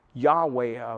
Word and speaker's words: Yahweh: We pronounce it Yahweh officially, but with that word is translated Jehovah.